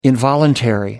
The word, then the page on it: involuntary: American English